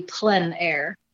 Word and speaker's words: plein air: It's, or properly pronounced, would be plein air.